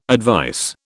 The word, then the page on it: advice: British English